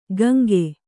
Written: ಕಾಂಕೆ

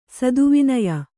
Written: ಸ್ತವನೀಯ

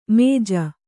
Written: ಮಾಜು

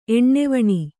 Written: ಎಣ್ಣೆವಣಿ

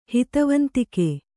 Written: ಹಿತವಂದಿಗ

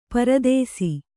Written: ಪರದೇಸಿ

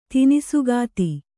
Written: ತಿನಿಸುಗಾತಿ

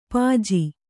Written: ಪಜ್ಜ

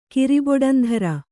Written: ಕಿರಿಬೊಡಂಧರ

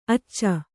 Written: ಆಚ

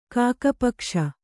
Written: ಕಾಕಪಕ್ಷ

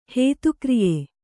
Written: ಹೇತುಕ್ರಿಯೆ

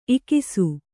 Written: ಇಕಿಸು

